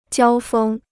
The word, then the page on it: 交锋: jiāo fēng